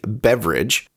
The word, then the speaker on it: Beveridge: Yes, his last name is legitimately Beveridge, but spelled like the crest of a hill or a ridge, rather than the liquid sense with an A-G in English. It's pronounced the same, though.